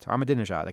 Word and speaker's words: Ahmadinejad: I learned how to say his name, the Iranian president, Ahmadinejad. I can say it now.